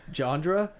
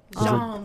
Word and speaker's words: genre: Okay, well, in French, it's genre. – Genre. There's a D, so. – In English, it's genre.